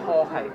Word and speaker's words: Jorge: Jorge. J-O-R-G-E. – Where'd you come from? – I'm from Manchester, up north. – Why'd they call you Jorge? – Well, it's everyone pronounces it George, but it's partly down to you as well.